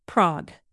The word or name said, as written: prague